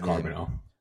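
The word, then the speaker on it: Charbonnet: I'll let you pronounce Zach Carbonell.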